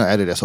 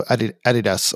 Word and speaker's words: Adidas: and so Adidas or Adidas is the right way to say it. Americans say it Adidas. We don't argue with them because they're by far the biggest global market for all this stuff, so they can call it whatever they want.